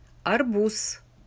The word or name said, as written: arbuz